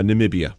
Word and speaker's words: Namibia: Is it Namibia? How do you say it? – Nam- uh, Namibia.